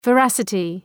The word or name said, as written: veracity